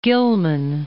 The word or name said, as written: Gilman